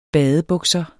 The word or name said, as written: badebukser